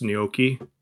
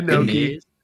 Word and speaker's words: gnocchi: It's pronounced gnocchi. oh, yeah. Man, that y- gnocchi.